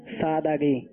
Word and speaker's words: سادگی: سادگی یا سادگی؟ – سادگی, یعنی دال کے اوپر جو ہے وہ زبر ہے.